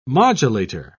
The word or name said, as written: modulator